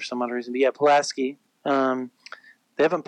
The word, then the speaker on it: Pulaski: It's Pulaski. Pulaski County is how it's pronounced. – Okay, then it's Pulaski. I used to live there. Then it's Pulaski Academy.